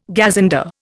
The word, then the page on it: gazunder: Indian